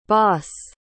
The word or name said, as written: bos